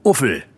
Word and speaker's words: UFL: Ja, also UFL, zusammen ausgesprochen Uffel.